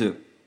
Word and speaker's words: œufs: Enfin, je vous rappelle que certains mots finissant par le son « œuf » au singulier se prononcent « e » au pluriel. Par exemple, un bœuf, des bœufs, ou dans notre texte, un œuf, des œufs.